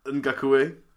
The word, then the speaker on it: Ngakoue: Ngakoue. – Yep, that's the not the one. Carry on. Yannick Ngakoue.